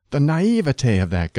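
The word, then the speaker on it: naïveté: Naivete combines naive and te, and standard word division calls for three syllables, naivete. Don't add a spurious fourth syllable. It is acceptable to stress the middle syllable, naivete, but the weight of authority favors the primary accent of the final syllable, naivete, which should rhyme with day, never with tea.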